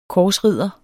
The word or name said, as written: korsridder